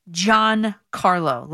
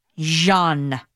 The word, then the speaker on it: Giancarlo: The name is pronounced Giancarlo, like J-O-H-N, Giancarlo, not Jean. That's not it. It's John. Giancarlo. One word. Okay? Just so everybody knows. It's an Italian name.